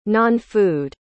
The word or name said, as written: nonfood